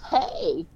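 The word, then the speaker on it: hej: I know you don't listen to me like ever, but anyways, it's hay. – Oh, okay. – Um, it's not spelled the same. Uh, it's H E J, but it's pronounced the same.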